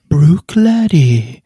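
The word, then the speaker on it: Bruichladdich: man skulle kunna tro att det uttalas Oban, men faktiskt så uttalas det Brookladdy. Väldigt eh, konstigt att det är så.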